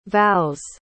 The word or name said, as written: vows